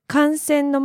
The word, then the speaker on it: 観戦: そうですね、うん、もし観戦の前に noun が来れば観戦でも、観戦するだったらば観戦なので、ちょっとアクセントが違います。